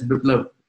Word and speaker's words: Biplab: Because is it actually Biplab, or like, am I even pronouncing it right? – Yeah, it's Biplab.